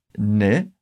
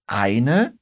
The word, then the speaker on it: eine: Só presta atenção que quando você fala ne ao invés de eine, soa um pouquinho mais coloquial.